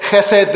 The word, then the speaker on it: chesed: Looks like chesed, the way it's spelt sometimes in a lot of commentaries, but that's the pronunciation in the Hebrew, chesed. And so what he's saying here, this word chesed that means love, kindness, pity, compassion, mercy, grace, loyalty, loving kindness is a word that tries to bring some of that together.